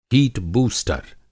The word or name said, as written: हीटबूस्टर